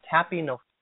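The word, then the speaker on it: tapeinophrosune: It's pronounced tapinophrosune. Tapinophrosune.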